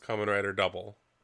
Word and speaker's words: Kamen Rider W: Like, Kamen Rider W is pronounced Kamen Rider Double.